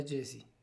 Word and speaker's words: Djesse: O sea, se pronuncia como Jesse, Jesse no, pues escribe D, J, E, S, S, E. La, la D no se pronuncia. The D is silent, igual que Django.